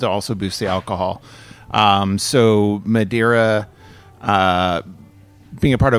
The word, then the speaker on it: Madeira: How do you say it? – Madeira. – Am I saying that right? – You just nailed it.